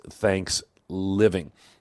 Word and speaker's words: Thanks Living: Again, I'm not like mispronouncing Thanksgiving. It's Thanks Living.